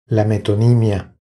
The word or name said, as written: La metonimia